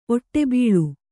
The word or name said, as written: ಓಡುಬಿಲ